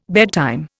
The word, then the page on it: bedtime: Indian